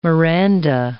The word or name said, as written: Miranda